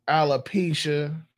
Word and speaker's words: alopecia: Now, I know it's all different types of diseases and, you know, and alopecia. Did I say that right? – Yeah, said it right.